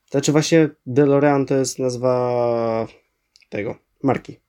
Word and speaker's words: DeLorean: E, jak się wymawia tą nazwę samochodu? DeLorean, dobrze. E,